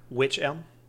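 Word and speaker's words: wych elm: Witch elm. – Yeah, but what one? – Witch elm? – Uh-huh. Which one? – Who's on first? – What? – this is spelled W-Y-C-H, okay? – Mm-hmm.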